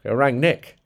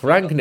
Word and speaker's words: Rangnick: Rangnick. Ralf Rangnick. – We're Rangnick, yeah. – You need your emphasis on the first part of the word, so Rangnick. – Rangnick. – And practice rolling your R's.